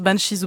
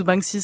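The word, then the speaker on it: Banxhies: Ça se prononce Banshees ou Banshees, c'est vraiment comme on veut.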